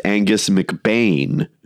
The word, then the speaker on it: McBean: Angus McBean! – By the way, I should say that Glenn Kay informed us that it's actually pronounced Angus McBain.